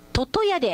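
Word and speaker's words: 魚矢: 湯村の、魚と矢と書いて、読み方はトトヤで合ってますよって、よかった、トトヤさんなんだ、もっちゃんトトヤさん、はい、ありがとうございます。ジャングルネーム通勤リスナーさん、教えていただきましてありがとうございます。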